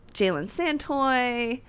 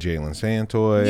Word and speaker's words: Jalen Santoy: So I really like like um, Jalen Santoy.